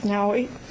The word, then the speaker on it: Navy: Young lady named Nawi, her name is spelled N-A-V-Y, but it's pronounced Nawi.